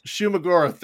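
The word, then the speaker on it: Shuma-Gorath: The first fight where they're fighting not Shuggoth Goroth, however you say his name, Shuggoroth, whatever. – Shumagoroth, there it is.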